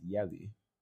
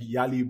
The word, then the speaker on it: Yale: Yeah, so I think the next thing that we have is pronouncing Yale as Yali. – Yo. – Now this one, I know that everybody is guilty of this. – Everyone is guilty. See, but even if you have like an American accent or whatever, the moment you spend time in Nigeria, you see Yali Cabin. – You have to pronounce it Yali.